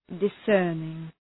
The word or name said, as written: discerning